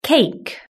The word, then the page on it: cake: British English